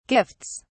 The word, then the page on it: gifts: British English